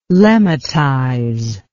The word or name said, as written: lemmatize